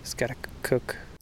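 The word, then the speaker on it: Schaghticoke: Skaticook. – The correct pronunciation is Skaticook, and I'm sorry in advance because you're going to have to hear me mispronounce it in my field tape quite a bit.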